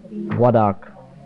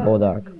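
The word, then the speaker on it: Bois d'Arc: B-O-I-S. Wa. And D-apostrophe A-R-C. So it's called Wadak. Or Bodark.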